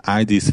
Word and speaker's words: id: And, is pronounced as in did or kid. That did id, vagy kid.